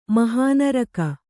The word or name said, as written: ಮಹಾನರಕ